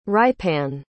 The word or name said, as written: rypan